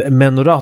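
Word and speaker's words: menorah: Heter det inte menorá, eller är det bara på engelska man säger så? – Menorá tror jag ligger närmare. Det är hebreiska uttal.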